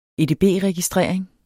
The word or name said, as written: edb-registrering